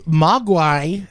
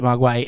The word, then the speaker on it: Mogwai: I will tell you, Mogwai, not the way it's spelt in this movie, but, you know, I think you say it Mogwai in like Cantonese, or whatever.